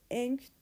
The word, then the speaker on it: Aang: Aang değil de Ang falan diye telaffuz ediyorlardı.